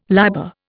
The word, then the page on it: liber: Indian